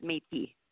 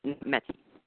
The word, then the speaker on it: Métis: Well, it was, except for it's pronounced Métis, not Métis.